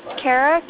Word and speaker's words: Kara: C-A-R-D-I-F. – Like different. – Okay. – Different. Okay, what was your name? – Kara, K-A-R-A.